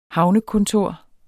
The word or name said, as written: havnekontor